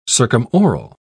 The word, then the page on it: circumoral: British English